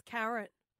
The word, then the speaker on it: Caron: So my name is C-A-R-O-N. – R-O-N. – Yeah. – Okay. – But pronounce Karen.